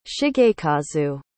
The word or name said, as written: shigekazu